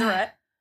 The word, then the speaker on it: Deseret: Pretty sure we've been saying that wrong. – We have now figured out that it's Deseret.